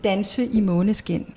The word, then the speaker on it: Danse i Måneskin: Oh, how do you say it again? – Danse i Måneskin.